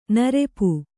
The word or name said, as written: ನೃಪ